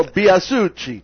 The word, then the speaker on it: Biasucci: I had Senator, by the way, State Representative Leo Biasucci, I like to call him. – I like it when you say that name. – You know? – Yeah, you say it correctly, you know. Leo Biasucci.